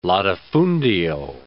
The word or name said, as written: latifundio